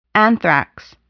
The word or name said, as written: anthrax